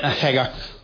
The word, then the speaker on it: Hagar: Her name, by the way, is not Hagar. She's not Hag. It's Hagar. Hagar. You know, he pronounced it right when he said... I'm glad that Jeff pronounced it right, because it really sounds horrible to say Hagar. It's a really horrible name in English, but it's Hagar. Hagar, if you could roll your R.